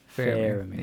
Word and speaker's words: Faramir: No, Faramir. – Fair. – Yeah. Oh, like F-A-I-R. – Yeah. – Like pronunciation. – Yes. – Faramir.